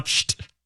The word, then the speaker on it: Pschitt: It's spelled P-S-C-H-I-T-T. And it is How would you pronounce that? – Because that's the sound of opening.